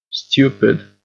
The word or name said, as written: stupid